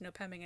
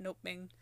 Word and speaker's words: Nopeming: So the winner was an Indian name, Nopeming, which is also why I think it's pronounced Nopeming and not Nopeming.